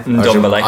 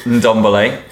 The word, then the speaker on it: Ndombele: Ndombele. – Ajabale. – Ndombele.